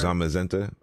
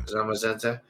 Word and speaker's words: Zamazenta: Zamazenta, I believe so. Yeah. – Zamazenta.